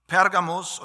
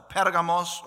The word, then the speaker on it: Pergamon: Was wir aber wissen können, ist, dass ganz gleich, wie auch dieser Name der Stadt, der antiken Stadt ausgesprochen wird, ob nun Pergamus oder Pergamos oder Pergamum oder Pergamon, es ist ein und dieselbe Stadt gemeint, die es heute noch gibt im Westen der Türkei und die heute Bergama heißt, Bergama.